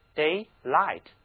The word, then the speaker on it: daylight: 这里的字母 gh 它是不发音的，听我读 daylight, daylight, 每天。